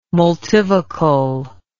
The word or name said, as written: multivocal